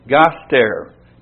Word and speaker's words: gaster: In the Greek, that is argos gartes, garter, sorry. And that is spelled A-R-G-O-S, Argos, and Gaster, G-A-S-T-E-R, Argos, Gaster.